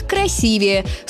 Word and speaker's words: красивее: Единственно верный вариант – красивее. С ударением на второй слог.